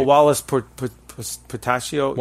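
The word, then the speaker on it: Whilce Portacio: What Works is an American comic book series created by artist Wilicek Portaco, if I'm saying that right. – Oh, Wallace Portacio.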